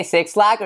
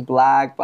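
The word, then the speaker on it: 6lack: No, it is Black. I've seen him in he talks about it in the song. He's like, yo, people will be calling me Six Slack, blah blah, but like it's Black, but I got more bills.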